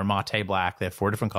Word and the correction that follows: matte: Did I just say mate black wrong? How do you say it? – It's matte.